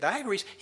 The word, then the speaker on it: Diagoras: And Diagoras, did I say his name? Yep, Diagoras, he had 3 boys.